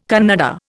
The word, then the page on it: Kannada: Indian